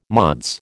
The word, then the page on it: Mods: American English